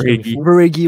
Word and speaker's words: Verhaeghe: Du côté de maintenant des Panthers, on vient de signer Carter Verhaeg. Je sais pas si je le prononce. – Verhaeghe, ouais.